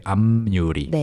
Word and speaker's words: 앞유리: The pronunciation is also interesting. It's 앞 with the p ending, 앞, right? 앞. But people say 앞유리.